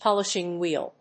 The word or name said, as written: polishing wheel